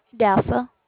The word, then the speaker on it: Dassah: Dassa. – Would you spell that, please? – D-A-S-S-A-H.